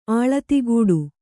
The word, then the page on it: ಆಳತಿಗೂಡು: āḷatigūḍu